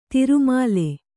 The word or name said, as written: ತಿರುಮಲೆ